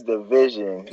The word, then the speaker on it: DVSN: Y'all say DVSN or y'all say Division? – I said DVSN, but I didn't know it. – Man, that thing is Division.